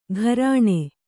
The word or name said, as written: ಗಾರಣೆ